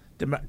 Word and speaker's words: Damar: Damar.